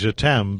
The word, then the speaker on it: Jhetam: We can be French and go as Jeetam, but I was about to say the same thing because it looks like that. – Jeetam, exactly. Jeetam.